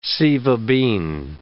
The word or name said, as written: sieva bean